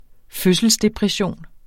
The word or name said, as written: fødselsdepression